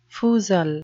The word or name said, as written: foozle